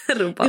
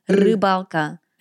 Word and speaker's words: Rybalka: Rybalka. Übrigens mit einem Ü, ne? Können viele Deutsche nicht aussprechen. Ü. Rybalka.